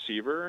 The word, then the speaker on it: Seaver: So again, my name is Jim Seaver, and I'm the Community Engagement Coordinator here at the Kentucky Historical Society.